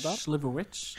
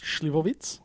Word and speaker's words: Slivovitz: How do you pronounce that? Slivovitz. – Slivovitz. – Slivovitz. – Yeah, that's how my mum told me to say it.